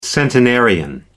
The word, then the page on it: centenarian: American English